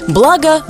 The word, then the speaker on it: благо: Вы совершенно правильно сакцентировали внимание на неправильной постановке ударения, потому что благо и блюдо. Поэтому то, что вас так сильно покоробило, коробит, конечно же, и нас. Поэтому нужно запомнить благо блюдо.